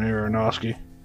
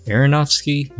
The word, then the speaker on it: Aronofsky: I'm pretty sure it's Darren Aronofsky. – Oh, excuse my French, Darren Aronofsky. Aronofsky?